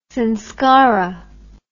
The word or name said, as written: samskara